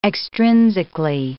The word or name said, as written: extrinsically